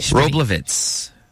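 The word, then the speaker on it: Wroblewitz: I can't pronounce her last name. What is it? – Roblovitz.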